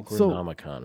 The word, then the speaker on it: Necronomicon: Did I say that right? – Necronomicon. – Yeah.